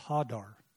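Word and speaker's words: hadar (הדר): Hadar is spelled H-A-G-A-R. But it's pronounced hadar.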